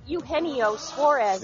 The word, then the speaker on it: Eugenio Suarez: But how do you say his name? – Eugenio Suarez.